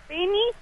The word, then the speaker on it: Penny: Bueno, mi nombre es Penny, que se escribe con P-E-N-N-Y, Rider, se escribe.